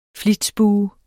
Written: Flitsbue

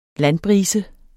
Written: landbrise

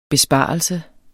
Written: besparelse

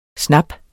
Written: snap

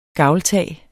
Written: gavltag

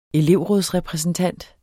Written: elevrådsrepræsentant

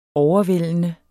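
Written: OVERVÆLDENDE

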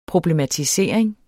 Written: problematisering

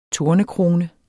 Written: tornekrone

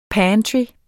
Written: pantry

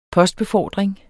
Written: postbefordring

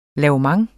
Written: lavement

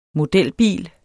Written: modelbil

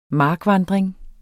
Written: markvandring

